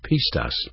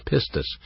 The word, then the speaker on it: pistis: Now, when I use the word faith, faith is a noun in the Greek New Testament pronounced pistis, pistis, P-I-S-T-I-S, and the verb counterpart is believe or pistuo.